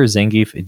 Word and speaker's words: Zangief: So this next one is Zangief the Red Cyclone, and and am I pronouncing his correct his name? Correctly? Zangief.